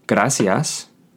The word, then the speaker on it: gracias: Spelled G-R-A-C-I-A-S. Keep in mind that this is not a double R, so you would not pronounce it gracias, but rather gracias. We will work on pronunciation in a later lesson.